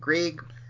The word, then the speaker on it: Greig: Tasman Greig. Is that Greg? Greig? I don't know how to pronounce her last Greig. – Name. – It's Greig.